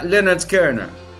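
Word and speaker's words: Lynyrd Skynyrd: Tal es así que el primer disco se pronuncia Leonard Skinner.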